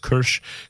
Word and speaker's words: Karsh: That's K-A-R-S-H. Looks like Karsh, but it's pronounced Kirsch. Kirsch and then K-A-L-E.